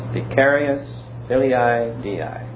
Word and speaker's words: Vicarius filii Dei: Vicarius filii Dei. – Yes, Vicarius filii Dei.